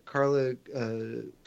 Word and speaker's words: Carla Gugino: I think his work with and relationship with Carla Gugino, mm-hmm.